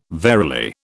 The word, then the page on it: verily: British English